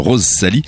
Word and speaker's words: Roszalie: Ça s'écrit R-O-S-Z. Attention, hein, attention, R-O-S-Z-A-L-I-E. Est-ce que vous connaissez David Scrima?